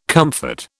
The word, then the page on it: comfort: British English